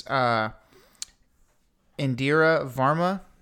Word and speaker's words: Indira Varma: Okay, the actress name is Indira Varma. – I'm sure you're saying that right. – Yeah, that's what it is.